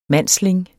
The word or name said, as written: mandsling